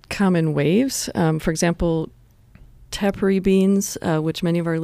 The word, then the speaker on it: Tepary: Tepary. – Tepary.